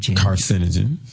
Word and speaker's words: carcinogen: Carcinogen. – There you go. – Okay, carcinogen.